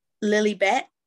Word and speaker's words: Lilibet: They invited Prince William, Kate, and their children to Lilybet. Am I saying that right? Yes.